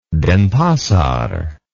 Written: Denpasar

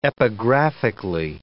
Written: epigraphically

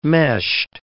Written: meshed

